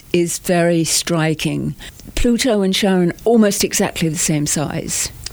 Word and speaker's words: Charon: And Charon.